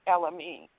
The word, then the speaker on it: Elamin: But my name is spelled T-H-E-R-E-S-A, last name Elamine, E-L-A-M-I-N.